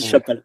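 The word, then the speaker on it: Chapalaz: au passage, c'est comme Flubelub, elle, elle est suisse et le Z ne se prononce pas en fin de, en fin des noms et du coup on dit Chapala.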